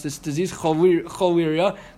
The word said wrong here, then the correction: cholera: right? How do you pronounce that in English? How is it? – Cholera, thank you.